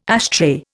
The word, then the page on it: ashtray: Indian